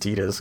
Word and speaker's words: Tidus: And it's an iconic sequence in the game because your main character, Tidus, even though it's spelled Titus, but it's pronounced Tidus according to Squaresoft, comforts Squaresoft.